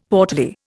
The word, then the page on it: portly: Indian